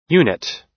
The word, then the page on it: unit: American English